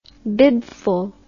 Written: bibful

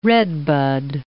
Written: redbud

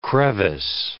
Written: crevice

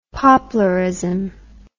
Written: poplarism

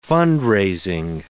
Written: fund-raising

